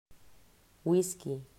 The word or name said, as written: Wisky